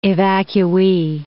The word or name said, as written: evacuee